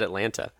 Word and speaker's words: Atlanta: It said, true or false, there are less than 2 T's in the correct pronunciation of Atlanta. – That sounds like Yankee talk to me. – I just said Atlanta, and I'm from Atlanta, which is how I usually say it.